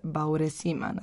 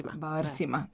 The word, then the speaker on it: Bauersima: Tekst je švajcarski pisac Igor Bauersima. – Bauersima. – Da, znači nisam dobro akcentovala.